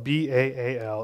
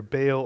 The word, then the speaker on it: Baal: spelled B A A L. It's pronounced either Baal or Baal. No one knows which one's right because it's a dead language and no one's heard it pronounced by the people who came up with that word in 2,000 years. Most people pronounce it Baal.